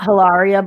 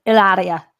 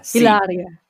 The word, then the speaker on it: Hilaria: And actually, it's not Hilaria Baldwin; it's Hilaria. – Ah, uh, Hilaria.